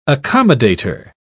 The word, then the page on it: accommodator: British English